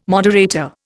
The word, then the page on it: moderator: Indian